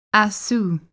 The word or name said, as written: Aszú